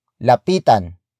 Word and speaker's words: lapitan: Word for word it's lapitan, mo, na, siya.